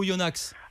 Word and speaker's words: Oyonax: Alors, Oyona ou Oyonax? – Alors écoutez, moi je l'appelle Oyonax, parce d'accord. – que je prononce toutes les syllabes.